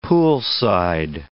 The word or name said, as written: poolside